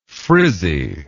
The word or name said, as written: frizziness